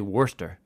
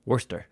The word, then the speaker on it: Worcestershire: It's spelled Worcestershire in English. – Yeah. – But he's saying in Scotland, they just say Worcester. – Worcester.